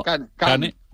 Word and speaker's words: Kane: Kane.